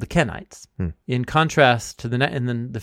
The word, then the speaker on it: Kenites: So it's a group of people called the Kenites, spelled K-E-N, like Ken, Kenites.